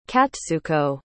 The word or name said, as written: katsuko